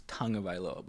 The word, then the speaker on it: Tagovailoa: It's Tagovailoa, but it's spelled like a T-A-U.